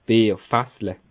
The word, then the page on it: Bierfässle: Bas Rhin; Schiltigheim